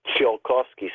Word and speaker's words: Tsiolkovsky: Tsiolkovsky, spelled with a T, T-S-I-O-L-K-O-V-S-K-Y, Tsiolkovsky.